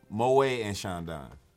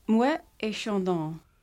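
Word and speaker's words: Moët & Chandon: Moet and Sean Don. – Still incorrect. – God damn. I keep my points I got? – Yeah, you keep that. – I feel like I should lose points for trying too hard. – How do you say it? – Moet et Sean Don.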